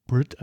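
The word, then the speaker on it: Bret: Und ja, es geht um die Band Flight of the Conchords, die Jermaine und Brett bestreiten, und er heißt ja Britt eigentlich, ne? – Da legt er ja auch Wert drauf, dass er Britt genannt wird, weil das die neuseeländische Aussprache ist.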